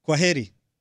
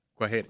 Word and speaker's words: Kwaheri: How do you say goodbye? – Kwaheri. – Kwaheri. – Kwaheri. – Kwaheri. Goodbye. – We know two things.